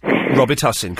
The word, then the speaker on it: Robitussin: and the correct way to pronounce it is Robitussin.